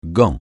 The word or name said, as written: gant